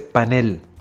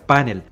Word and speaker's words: panel: Debe escribirse y pronunciarse panel, porque es una palabra aguda cuya fuerza de entonación está en la última sílaba. Por lo tanto, debe evitarse la grafía panel con tilde en la A, así como la pronunciación grave panel, propia de la raíz de origen en inglés.